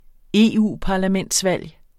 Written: EU-parlamentsvalg

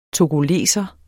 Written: togoleser

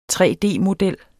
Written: 3D-model

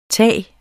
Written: tag

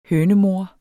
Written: hønemor

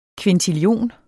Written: kvintillion

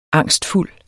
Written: angstfuld

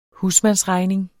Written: husmandsregning

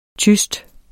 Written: tyst

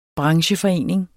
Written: brancheforening

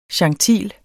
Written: gentil

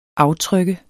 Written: aftryk

